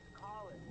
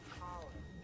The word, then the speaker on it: Colin: Is it Colas? – It's Colin. – Colin. – Yeah, the O is silent. – Yes, sir. Oh, so it's Clint? – It's Colin. It's like Colin.